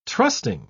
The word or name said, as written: trusting